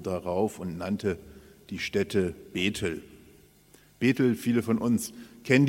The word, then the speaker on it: Bet-El: Bethel, wenn man es richtig hebräisch ausspricht, würde man sagen Bet-El, also in zwei Silben, Bet-El, und das heißt Bet, das Haus, und El ist Gott, also das Haus Gottes, ein Gotteshaus.